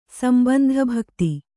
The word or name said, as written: ಸಂಬಂಧಭಕ್ತಿ